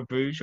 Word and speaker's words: Brugge: Uh, 4 uh, 0 for Bruges or Brugge, depending how you want to pronounce it.